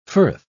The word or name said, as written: firth